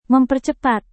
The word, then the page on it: mempercepat: Indonesian